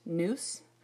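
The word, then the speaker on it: nous (νοῦς): And so this word, think or mind, in the Greek, it's pronounced nous, and it means comprising of faculties of perceiving and understanding, also feeling, judging, and determining.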